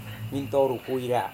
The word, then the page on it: mintoru-kuira: Serewen